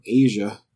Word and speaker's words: Aja: So if you guys haven't heard the album Asia, A-J-A, so it's spelled, by Steely Dan, that's probably the best album.